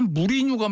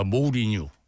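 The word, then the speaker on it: Mourinho: 그 양반도 우리는 다 무리뉴 감독이라고 그러잖아요. 그 모리뉴가 맞습니다. 모리뉴. 예. 발음이 그렇게 됩니다.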